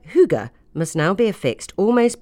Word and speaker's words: hygge: How to Find Your Special Cozy Place suggests that the crucial word be pronounced hygge.